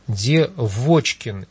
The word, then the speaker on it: Девочкин: Она просит ее, просит Татьяну Леонидовну объяснить происхождение фамилии Девочкин. Именно так, с ударением на второй слог.